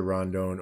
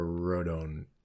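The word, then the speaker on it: Rodón: Everyone pronounces it either Rondon or Rodon. It is Rodon. Rodon, right? Rodon? Yeah. Rodon, yes. – Yeah, that's Rodon.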